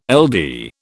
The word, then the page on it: LD: British English